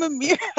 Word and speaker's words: Amirah: There's no S in my name. It's spelled I'm like, where is he? Where is he? And she's like, it's Amira. I'm Amira. – So wow, my name is spelled A-M-I-R-A-H.